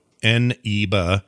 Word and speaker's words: Eneba: It's Aniba. It's spelled E-N-E-B-A. So Aniba, .com.